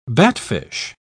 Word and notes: batfish: British English

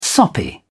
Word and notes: soppy: British English

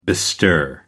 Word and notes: bestir: American English

